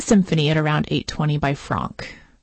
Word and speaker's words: Franck: It's by the composer Gabriella Lena Franck.